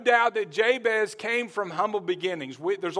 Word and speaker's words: Jabez: Jabez, the actual name, is a Hebrew word that's pronounced Yabetz.